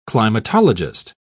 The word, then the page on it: climatologist: British English